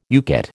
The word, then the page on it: UCATT: American English